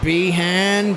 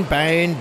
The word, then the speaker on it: Behan: Yes, Dylan Behan, Bain, Behan, I don't know how to pronounce it. Yes, B-E-H. Sorry, Behan.